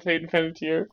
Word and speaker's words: Panettiere: There are two names where I feel extremely douchey when I pronounce them correctly, and that's Hayden Panettiere, and it's Guy Fieri. and I know those are the correct pronunciations because they've said them.